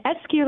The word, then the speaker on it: Escue: It's Escue like rescue.